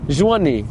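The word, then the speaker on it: Joigny: Is that how you pronounce it? – Joigni. – Thank you. Joigni.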